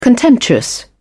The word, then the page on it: contemptuous: British English